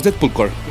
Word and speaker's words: Deadpool Corps: Y llegó una nueva serie, los Deadpool Corps, o los Deadpool Corps, eh, eh, pronunciado correctamente.